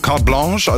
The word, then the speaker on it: Carte Blanche: und wird aber, Carte Blanche, also französisch ausgesprochen.